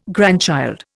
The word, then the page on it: grandchild: Indian